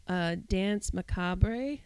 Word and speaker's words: macabre: And then I found out that there is a British pronunciation, because it's a French word. – Correct. – And it's Dance Macabre. But it's really, the emphasis is so light on the R that you can just drop it.